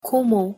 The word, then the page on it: 고모: Korean